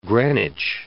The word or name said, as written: Greenwich Village